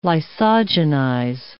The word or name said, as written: lysogenize